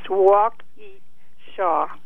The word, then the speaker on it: Waukesha: The pronunciation of that city in Wisconsin is Waukesha.